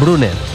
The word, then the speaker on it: Brunner: Paris Brunner, con dos N's, Brunner.